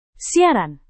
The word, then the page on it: siaran: Indonesian